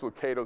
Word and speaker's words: Lucado: There's a great quote by Max Lucado, or Lucado. I guess Lucado is the actual pronunciation.